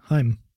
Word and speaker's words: Heim: I think it's just like that. Yeah, Heim.